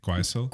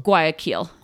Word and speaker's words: Guayaquil: Guayaquil. – Guayaquil. Guayaquil.